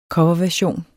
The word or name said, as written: coverversion